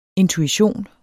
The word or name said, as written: intuition